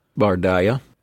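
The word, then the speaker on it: Bardiya: Bardaya. Is that the way you would pronounce it? – I would say Bardaya.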